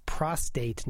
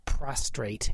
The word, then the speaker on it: prostate: And it's prostate, not prostrate, and it's cefazolin, not cefazolin.